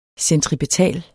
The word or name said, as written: centripetal